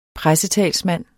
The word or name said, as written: pressetalsmand